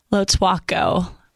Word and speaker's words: Lotuaco: Lotwako, like a W.